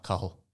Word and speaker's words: Cathal: Sean Mason, Tommy Doyle, Cathal Lanigan. Did I pronounce that right? – No, Cahill.